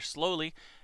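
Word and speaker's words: Sloly: The police chief, his name was Peter Slowly, S-L-O-S-L-O-L-Y. But I think that's great because it's spelled differently than Slowly, but when you say it, it sounds like Slowly.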